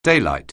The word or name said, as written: daylight